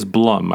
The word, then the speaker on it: Blum: Is it Blum or is it Blum? – I don't know the answer. – It is Blum.